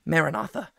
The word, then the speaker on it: Maranatha: But the way that that is going to come can be summarized in a single Aramaic word, Maranatha, how we usually pronounce it in English, Maranatha. Right?